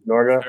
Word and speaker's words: Norge: N-O-R-G-E, Norga? – Very well, very well.